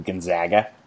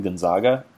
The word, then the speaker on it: Gonzaga: But the thing with Gonzaga, and there's so many people like situated in Eastern Washington that are spitting mad that we're not calling them Gonzaga. So many people. Because that's how you're apparently supposed to pronounce it.